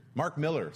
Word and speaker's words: Mark Millar: Mark Millar is weird. Mark Miller, sorry, I always say it wrong because of the way it's spelled, but it's Mark Miller.